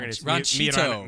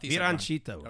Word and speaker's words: Mi Ranchito: Mi ranchero. – No. Okay. I-T-O. I can pronounce that. Like, or maybe I'm getting it wrong. Ranchito. – Oh, Ranchito. – Mi Ranchito.